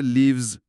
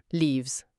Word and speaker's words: leaves: شما به صدای آخر leaves دقت کنید، صدای vz میده. – leaves.